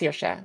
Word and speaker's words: Saoirse: I mean, you know, the name itself is pronounced Saoirse,